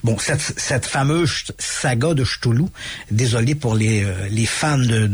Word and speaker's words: Cthulhu: Cthulhu. Et dans ce mythe de Cthulhu, c'est un peu difficile à dire parce que ça s'écrit C-T-H-U-L-H-U.